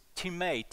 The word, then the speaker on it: timē: The Greek word is timē, T-I-M-A-T-A, T-I-M long E, timē.